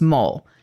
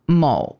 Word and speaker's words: mole: I still think that Jason and I are on the right side of history because the way you were saying it, even if it's pronounced mole, I was going for the German. – The way you were saying it made me think of an actual mole and not mole, which is how I think it is pronounced. Mole, not mole.